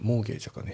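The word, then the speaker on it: mortgage: あとモーゲージはね、それモーゲージかね、 T を発音しないですよね、モーゲージ、ね、で次行って、 Can you help me with that?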